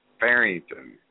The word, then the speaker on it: Farrington: It's Bobby Farrington.